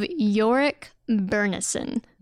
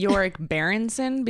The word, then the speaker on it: Iorek Byrnison: Jesus Christ, of Iorek Byrnison. Like, all right, this is the definitive. We know how to say this dude's name now. Yes. Iorek Byrnison. Well, I'm still gonna say Iorek Berenson because I like that better.